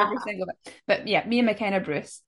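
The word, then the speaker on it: Mia McKenna-Bruce: Because I know you've got Mia McKinley Bruce. Have I said her name wrong? McKenna Bruce. – There we go. – Mia McKenna Bruce.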